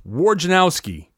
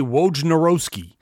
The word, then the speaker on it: Wojnarowski: I know that, according to Adrian Wojnarowski, and by the way, side note, it's pronounced Wojnarowski. He says it multiple times. People still continue to say Wojnarowski. You don't say whatever Mike Krzyzewski's name is pronounced phonetically. You say Mike Krzyzewski. Say Adrian Wojnarowski. That's his name.